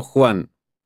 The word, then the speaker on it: Juan: Me llamo Juan. Se escribe J-U-A-N.